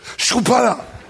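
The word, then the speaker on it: Chrupalla: Warte, Schrupalla spricht man so. Schrupalla.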